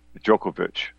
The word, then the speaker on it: Djokovic: And it pronounced Djokovic is how to pronounce his name, Djokovic.